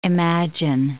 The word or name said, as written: imagine